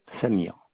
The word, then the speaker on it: Samir: C-H-E-R-G-U-I. – Monsieur Chagui. – Voilà. – Et votre prénom, vous m'avez dit? – Samir. Samir. S-A-M-I-R. – C'est vrai que je m'entends mal. Ça... – S-A-M-I-R.